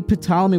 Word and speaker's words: Ptolemy: King Ptolemy with a silent P, that he forced 72 scholars into 72 separate rooms.